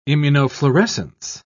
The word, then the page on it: immunofluorescence: British English